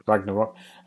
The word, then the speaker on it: Ragnarok: Have I said that right? – Ragnarok.